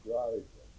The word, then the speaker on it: Grice: How do you spell that? G-R-I-C-E. But we call it Grease. – But in those days you called him Grease.